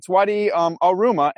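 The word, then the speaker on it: Wadi al-Rumah: Even when we asked some locals, because we didn't know if we were pronouncing the name of the Wadi right, they said, yeah. It's Wadi al-Ruma, and it runs from Medina in the Hejaz Mountains all the way to Iraq, where the Tigris and Euphrates join.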